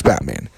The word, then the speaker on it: Batman: And so this province, and it is pronounced from what I've, read, read, read and researched, it's pronounced Batman. And actually, several years ago, uh, when Batman was getting popular, they were making uh, really popular, movies were coming out.